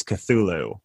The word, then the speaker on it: Cthulhu: It's Cthulhu, but the great thing about Lovecraft is that he didn't settle on a spelling or pronunciation during his life, so you can pretty much say anything, but the pretty much standardized pronunciation is Cthulhu.